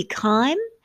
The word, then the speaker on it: Keim: It's spelled K-E-I-M. Although the phonetic pronunciation would be Keim or Kame, the German pronunciation would be Keim, which I find more euphonious, so Keim it is going to be.